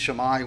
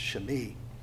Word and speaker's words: Shimei: Now, we usually pronounce it Shammai, which is probably phonetically is what it looks like. It's probably pronounced Shimei.